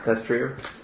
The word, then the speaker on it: destrier: Destrier. – Destrier.